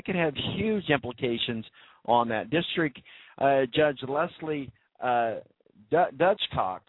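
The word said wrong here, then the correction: Dutchcot: It's Cott. Dutchcott. Get your minds out of the gutter. Dutchcott. Just wanted me to say it again, didn't you? Oh, the Balance crew, these guys rock, let me tell you. Never miss a point. District Judge Leslie Dutchcott. That's how you say it.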